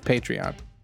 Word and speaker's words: Patreon: And no, and I even mispronounced it. I said, kept saying patron. It's Patreon.